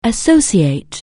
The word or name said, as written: associate